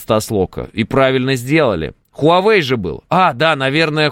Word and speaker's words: Huawei: Huawei, правильно говорить.